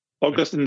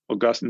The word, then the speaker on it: Augustine: Augustine is a city in Florida. Augustine is a saint of the church.